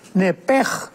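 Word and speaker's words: nepej (נפש): La Biblia le llama el alma, pero la palabra hebrea es muy importante porque es la palabra nepesh, con SH al final, nepesh. En el hebreo se pronuncia la SH con J, entonces la pronunciación hebrea es nepej, al salirse el nepej, el alma.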